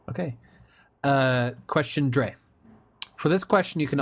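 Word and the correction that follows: Drei: It's pronounced dry.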